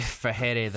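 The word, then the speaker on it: Freire: Uh, guy who knocked out uh, Nate Diaz, D- Diaz, former UFC vet, and also Patricky Fure, Fure, Fure, Fure, Patricky Fahere, the Fahere. Pitbull, the Thank pitbull you. Fahere. Thank you.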